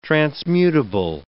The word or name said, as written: transmutable